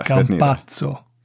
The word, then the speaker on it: Campazzo: Ja, Campasso. Jag vet fan hur man uttalar det. – Campazzo. – Campazzo till och med, okej.